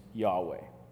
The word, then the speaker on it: Yahweh: Now, the big reconstruction of all the rules of grammar in ancient Hebrew, it's a big debate, but the closest pronunciation that we could know for that divine name today is Yahweh, something around there.